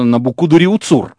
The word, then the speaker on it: Навуходоносор: Вот мы даже ударение у него перепутали, причем, знаете, перепутали по такому же принципу, как библейский царь Навуходоносор, понимаете? Он вообще Навуходоносор был. А если совсем точно, Набукудуриуцур.